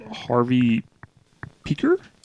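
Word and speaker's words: Harvey Pekar: That's a Paul Giamatti flick, autobiographical about Harvey Peaker. – Yeah, that is how it's pronounced. It looks like something else, but it's I got myself.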